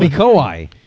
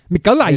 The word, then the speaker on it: Mikołaj: Oh, Mikołaj. That's what you're trying to say. – What is it? – Mikołaj. – The J is pronounced the I. Whatever. Mikołaj. – It's Mikołaj.